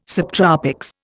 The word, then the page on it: subtropics: Indian